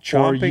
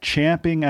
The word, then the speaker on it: champing: ...want to argue that it's now become a part of the lexicon because all you idiots have said it wrong for so long... I'm not buying it. It's champing at the bit.